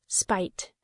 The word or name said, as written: spite